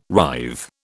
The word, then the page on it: writhe: British English